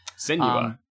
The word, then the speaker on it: Senua: Senua. Is that actually how it's pronounced in the game too? – Yes. Senua.